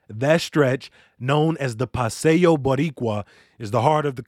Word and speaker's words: Paseo Boricua: It's pronounced Paseo Boricua. – Paseo Boricua.